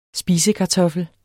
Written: spisekartoffel

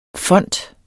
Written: font

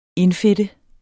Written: indfedte

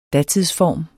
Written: datidsform

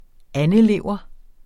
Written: andelever

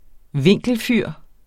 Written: vinkelfyr

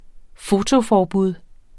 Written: fotoforbud

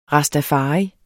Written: rastafari

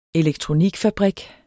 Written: elektronikfabrik

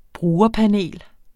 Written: brugerpanel

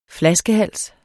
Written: flaskehals

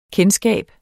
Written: kendskab

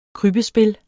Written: krybbespil

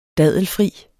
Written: dadelfri

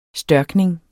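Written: STØRKNING

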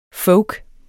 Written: folkens

